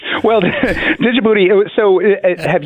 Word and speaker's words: Djibouti: But I noticed you didn't mention my favorite country in Africa, Djibouti. I realize that some people think that you drop the D and just call it Djibouti, but it's my favorite country, and I can pronounce it any way I want.